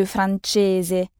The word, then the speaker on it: francese: E-S-E en italien. Francese, inglese. Et vous remarquerez aussi le son ch de francese.